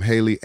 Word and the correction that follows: Halle: Hallie, is it Hallie or Haley? I want to say that right. – I think it's Halle. – I think it's Halle, right? – Yeah.